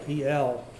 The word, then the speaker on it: El: In both names, we find that word, El, E-L. Brother Thomas says the correct pronunciation is El, which has the idea of power, might, or strength.